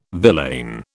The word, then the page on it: villain: British English